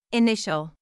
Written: initial

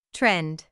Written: trend

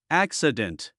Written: accident

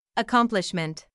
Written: accomplishment

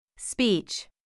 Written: speech

